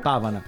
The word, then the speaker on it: Pavana: Pavana eh, non so se l'accento è giusto Arcadio sì. Pavana ok quindi anche riportare a,